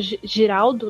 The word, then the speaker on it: Giraldo: E sem contar que ele ganhou uma semifinal do Santiago Girardo, que é outro que tá voltando pro tênis, o Santiago Giraldo, né? Desculpa, gente.